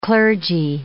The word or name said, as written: clergy